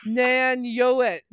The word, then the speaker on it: Nanuet: Nanuet.